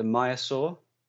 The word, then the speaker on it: maiasaura: Yeah, so the oh, the- they alternate between calling it a maiasaura and a maiasaur. Most yeah, but maiasaura. – So it's like got an S name. on the end um, here on the map. Do you not pronounce the S? Do you drop that right at the end? – I think it's Maiasaura because they wanted it to be a feminine name.